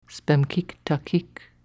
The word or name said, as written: spemkik takik